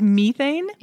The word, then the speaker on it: methane: She says methane? – Yes, she says methane. And I'm imagining that is the British English pronunciation.